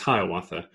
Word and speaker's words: Hiawatha: A major casualty of these times and this specific, probably the specific instance, was a man named Hiawatha, which, you know, is pronounced generally as Hiawatha.